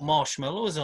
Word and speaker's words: marshmallow: Well, it's not pronounced like you've pronounced it. That's what I want to say. – How do you pronounce marshmallow? – Well, it's mallow, isn't it? Marshmallow with an A.